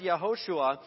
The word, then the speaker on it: Yehoshua: But the name Joshua, it's a Hebrew word, obviously, a name, and it's pronounced Yehoshua.